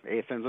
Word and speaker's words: Athens: here Athens is pronounced with a long A instead of a short A.